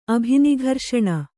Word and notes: ಅಭಿನಿಘರ್ಷಣ: abhinigharṣaṇa

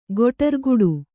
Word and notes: ಗುಡಾರಗಟ್ಟು: guḍāragaṭṭu

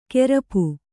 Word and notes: ಕೇರ್ಪು: kērpu; Kannada

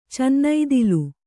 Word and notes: ಚನ್ನೈದಿಲು: cannaidilu

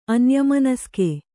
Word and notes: ಅನ್ಯಮನಸ್ಕೆ: Kannada

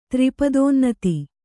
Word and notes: ತ್ರಿಪದೋನ್ನತಿ: tri padōnnati

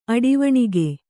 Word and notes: ಅಡಿವಣಿಗೆ: Kannada

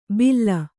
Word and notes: ಭಿಲ್ಲ: bhilla

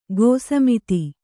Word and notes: ಗೋಸಮಿತಿ: gōsamiti

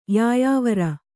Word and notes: ಯಾಯಾವರ: yāyāvara

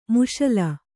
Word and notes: ಮುಶಲ: muśala

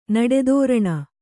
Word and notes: ನಡೆತೋರಣ: kannada

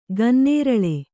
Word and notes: ಗನ್ನೇರಳೆ: gannēraḷe

